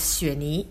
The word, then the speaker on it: Xue Ni: So if you put all these things together, it will mean my dad, you know, the Xue from my dad's name, and then deer from my mom's name, and then the daughter. – So how do you pronounce that altogether? – Altogether is Xue Ni.